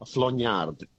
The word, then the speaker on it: flaugnarde: So FLONYARD, flognard, if I was Australian.